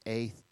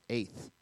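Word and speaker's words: aith (את): The word is aith, A-Y-T-H, aith is how you pronounce that.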